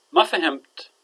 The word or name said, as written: maafahimt